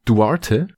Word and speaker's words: Duarte: Chris äh, Duarte, spricht man denn so aus? – Ja, ja, passt.